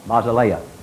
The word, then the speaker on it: basileia: The E-I has a long A sound, basileia.